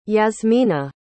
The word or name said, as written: Yasmina